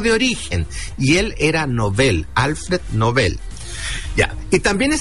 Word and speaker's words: Nobel: Ambas palabras son agudas, por lo tanto, su pronunciación adecuada es Nobel.